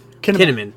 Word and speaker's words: Kinnaman: Kinnaman is a pretty great actor.